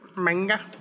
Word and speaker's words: manga: The magma. I can't say it right. – Yeah. – Whatever it is. – Manga. – Manga. Thank you.